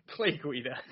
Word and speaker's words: Guida: Clay Guida.